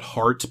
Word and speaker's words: Haerts: There's another band I want to mention called Hearts, but it's not the 80s Hearts. – Yeah, it's spelled h-a-e-r-t-s.